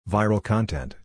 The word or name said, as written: Viral Content